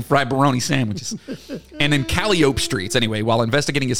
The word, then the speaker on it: Calliope: Wrong. – Calliope. – It's Calliope is how they pronounce it. – Calliope. – I know, but there's so many streets. There's a street that's Burgundy, but they pronounce it Burgundy. It's fucking dumb. It's Calliope. – Calliope. – That's how it's pronounced.